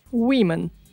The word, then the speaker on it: women: Woman, women. Меняется только одна гласная a на i но слово произносится совершенно по-другому. – Насколько мне известно, это единственный случай за весь английский язык, где буква O читается как I.